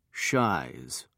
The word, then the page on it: shies: British English